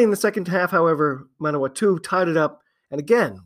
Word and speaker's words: Manawatu: I had been saying Manawatu, and it's really Manawatu.